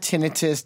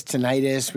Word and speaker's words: tinnitus: So, so first of all, you've got tinnitus, tinnitus. We pronounce it both ways, either way, you know, and COVID.